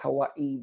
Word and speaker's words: Hawaii: And by the way, it's pronounced as Hawaii based on the local.